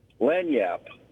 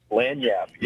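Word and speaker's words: lagniappe: And how do you say it? – Lanyap. – Lanyap? – Lanyap.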